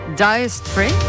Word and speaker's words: Dire Straits: Esto es Dire Straits.